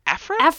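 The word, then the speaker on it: Aphra: It came out under Dr. I'm not sure how to say her name. Afra. Okay, cool. Uh-huh. I've been saying it right.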